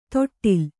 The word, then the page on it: ತೊಟ್ಟಿಲ್: toṭṭil